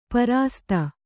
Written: ಪ್ರಸ್ತ

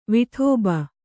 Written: ವಾಡಬ

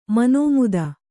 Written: ಮನುಮತ

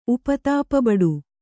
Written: ಉಪತಾಪಬಡು

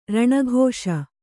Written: ರಣಘೋಷ

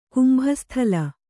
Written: ಕುಂಭಸ್ಥಲ

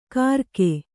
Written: ಕರಕೆ

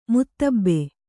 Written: ಮುತ್ತಬ್ಬೆ

